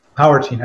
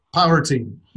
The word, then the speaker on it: Powrtein: Powrtein. I said that right. Right? – Oh, yeah. It's a play on two words, protein and power. And it's Powrtein.